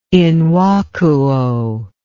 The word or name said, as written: in vacuo